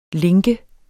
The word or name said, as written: link